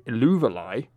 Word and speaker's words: Eluveitie: I'm instead going to recommend a band called Illuvalai. – Illuvalai? – Yes. Which is spelled, this is going to be fun, E-L-U... V-E-I-T-I-E, which does not sound like it's supposed to. – Could you repeat that again for any possible listeners? Or? – E-L-U-V-E-I-T-I-E.